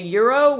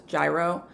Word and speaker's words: gyro: And it can also be in a gyro, which is something Americans often pronounce as gyro.